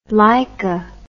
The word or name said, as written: Laika